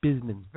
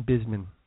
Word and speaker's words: Busman: Would that be B-I-Z-M-E-N, Bisman? – B-U-S-M-A-N. It's Busman. You'd think it would be Busman. – You would think that. – Yeah, no, it's pronounced Bisman. – Very well, then. – Right. John Bisman.